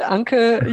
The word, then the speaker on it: Anke: Like Danke, you know, German Danke, Anka, you got it.